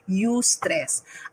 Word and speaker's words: eustress: Alam nyo, there is such thing as called eustress.